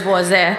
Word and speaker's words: Voisin: Instagram is Valley Voisin because my last name is Voisin. – How do you spell that? – V-O-I-S as in Sam, I, N as in Ned.